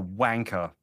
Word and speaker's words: wanker: So the last syllable, say wanker.